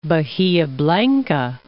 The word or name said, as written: Bahia Blanca